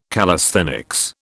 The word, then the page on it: calisthenics: British English